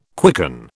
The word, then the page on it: quicken: British English